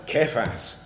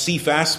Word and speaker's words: Kephas: And others again, "I have Kephas." The word here is so often pronounced Cephas, but the word comes from Kephale, which is a stone. That's what his name means.